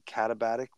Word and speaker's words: katabatic: like the katabatic winds, am I saying that right? – It sounds right.